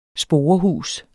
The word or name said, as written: sporehus